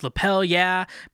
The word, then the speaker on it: Lapel Yeah: Our sponsor for this episode is Lapel Yeah, because it rhymes with hell yeah, as in give me a lapel yeah.